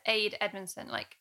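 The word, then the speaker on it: Ade Edmondson: Is it Aid Edmondson, as in Jennifer Saunders' husband? – Oh, yeah.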